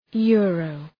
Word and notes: euro: ‘ju:rəʋ

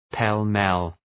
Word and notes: pell-mell: pel’mel

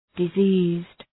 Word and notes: diseased: dı’zi:zd

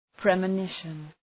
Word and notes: premonition: ,pri:mə’nıʃən

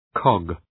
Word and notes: cog: kɒg

